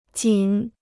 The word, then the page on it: 仅: jǐn